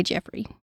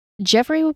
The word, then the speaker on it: Geoffrey: Geoffrey, G E O F F R E Y. I'm going to say Jeffrey. Hood and Molly LaRue.